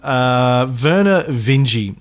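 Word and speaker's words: Vernor Vinge: My guest today is a legend in his own lifetime, Werner Vinge. And yes, that is the correct pronunciation, I know, because I asked him.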